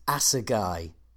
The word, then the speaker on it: Assegai: I remembered Project Assegai. That's the correct pronunciation, isn't it? – Yeah, that's it.